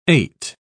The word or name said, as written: ate